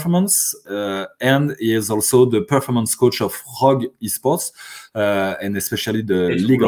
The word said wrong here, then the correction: Rogue: It's pronounced Rogue. – Oh, Rogue. Sorry. – That's so French.